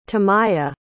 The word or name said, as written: tamya